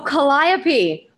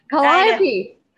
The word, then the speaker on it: Calliope: Oh, Calliope. Mispronounced it. Calliope.